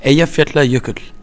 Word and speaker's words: Eyjafjallajökull: That's a hard one. Ending on a tl. – Eyjafjallajökull.